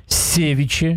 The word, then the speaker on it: севиче: Севиче или севиче, кто там? – Севиче, наверное. – Нет. Ударение ставится и так, и сяк. Да? Хотите, так и называйте.